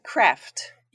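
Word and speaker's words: Craeft: Before I say anything else, I should ask, did I say that right? CREFT? – Yeah, it'll do. I mean, it depends from where you are in the country. Craft. Craft maybe, but perhaps a slight Scandinavian take on it, craft.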